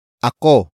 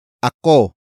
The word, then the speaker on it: ako: The next word is ako. Ako. Ako. Ako is spelled as A-K-O.